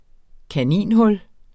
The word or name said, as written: kaninhul